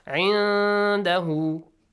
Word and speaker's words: عِنْدَهُ: remember that you should hide the sound. It pronounce the noon with a hidden sound. 'Indahu, not عِنَّ. عِنْدَهُ, no. Noon shouldn't be clear.